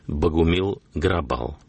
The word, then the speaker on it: Богумил Грабал: Богумил Грабал. В русском произношении Богумил Грабал.